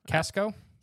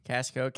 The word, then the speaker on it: Cascio: It's not Costco. – Okay. – It's not related. Paul, it's not your relative. It's spelled C-A-S-C-I-O. – Okay. C-A-S-C-I-O. – Casco? – Casco, Casco.